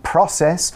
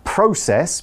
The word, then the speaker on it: process: Yeah, and Mike very kindly described a process yeah. or process, both pronunciations are okay.